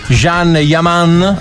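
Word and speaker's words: Can Yaman: Eh sì, perché Kanjaman è furioso su Instagram per la finta paparazzata. – Ecco, okay. Tanto arriveranno adesso, non si dice così, si dice Jean Yaman.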